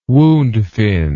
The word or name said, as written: woundfin